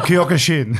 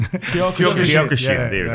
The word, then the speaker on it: Kyokushin: Just it's called Kyokushin. – Kyokushin.